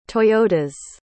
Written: toyotas

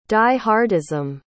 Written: die-hardism

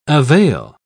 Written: avail

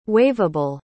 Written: waiverable

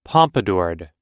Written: pompadoured

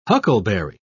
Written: huckleberry

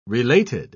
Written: related

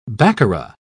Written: baccarat

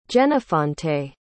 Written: jenofonte

